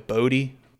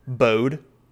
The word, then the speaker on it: Bode: Oh, what did you say before? – Bode. That's what I said name. Before. Okay. Because phonetically, that's how it's pronounced. – B-O-D-E. Bode.